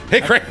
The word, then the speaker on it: Hickory: Hickory! You got to say it like that.